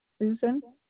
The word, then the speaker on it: Susan: G-A-R-Y. – Yes, ma'am. May I have your first name? – Susan. – Could you spell that for me? – S-U-S-A-N.